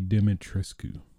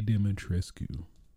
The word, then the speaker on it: Dimitrescu: her name, Lady Demetrescu. I'm totally butchering that. Lady Dimitrescu. That's what I'm going to say. Lady Dimitrescu.